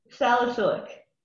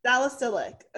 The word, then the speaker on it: salicylic: How do you pronounce it? – Salicylic. – Salicylic. Okay. – There we go.